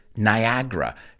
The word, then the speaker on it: Niagara: You look at it and it's Niagara, right? But we pronounce it Niagara, Niagara. That second A. There are three A's in the word. The second A is basically silent.